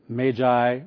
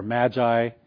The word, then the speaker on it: magi: And it's the word, it's pronounced in different ways, but it's magi or magi.